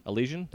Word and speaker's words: Elysian: So I'm drinking on this, I think it's pronounced Elysian. Elysian. There you go. She knows it.